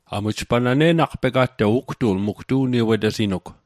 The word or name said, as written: ne'naqpega'toq